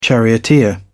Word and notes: charioteer: British English

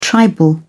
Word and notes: tribal: British English

